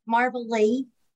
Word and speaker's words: Marvelle: Marvelle.